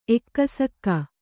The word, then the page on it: ಎಕ್ಕಸಕ್ಕೆ: ekkasakke